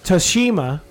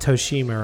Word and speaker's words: Tsushima: Ghost of Tsushima, or Toshima. – Tsushima? – Tsushima? I've been Tsushima? Saying it wrong. Dara's like, you know it's not Toshima, right?